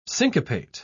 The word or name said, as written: syncopate